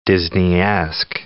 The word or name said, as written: Disneyesque